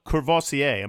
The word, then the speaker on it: Courvoisier: John, let's get into racing a little bit because we saw Courvoisier. Am I saying that right? – Yeah, just like the Cognac. – Brandy. Just like the brandy, right? – Yes, perfect. Yeah.